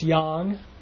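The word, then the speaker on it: yang (羊): It's pronounced yang, and that is how the character for oxygen is pronounced, yang.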